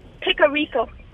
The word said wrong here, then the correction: pecorino: I think it's pecorino.